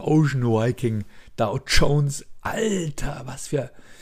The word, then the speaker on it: Ocean Viking: Genau, die Ocean Viking, dieses Flüchtlingsschiff oder das Schiff, was die Flüchtlinge aufgenommen hat, die Ocean Viking, das heißt Ocean Viking, ja, das ist ein V. Das spricht man nicht Hur, nicht Viking.